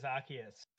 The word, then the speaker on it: Arceus: Am I pronouncing that right? Is it Arceus? – It is Arceus. – Arceus. – Arc of electricity.